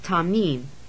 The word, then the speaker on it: tamim: And in Hebrew, that's tamim. It's pronounced T-A-H-M-E-E-M.